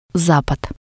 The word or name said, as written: zapad